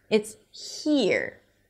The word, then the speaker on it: here: It's not a regular here. It's here. It's like tight, tense sound. Here.